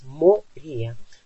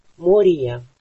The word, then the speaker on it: Мория: Кстати, обратите внимание на ударение. Оно почти в русском, ну как нам хочется сказать Мария. Но это О, оно должно звучать. Мория. Хотя это безударное. Мория.